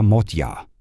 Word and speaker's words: Magyar: Im Ungarischen wird immer die erste Silbe betont. Das A wird zudem geschlossen ausgesprochen, also ein bisschen wie ein O, und GE ähnelt eher DJ. Peter Magyar klingt im Deutschen dann so Peter Modyar.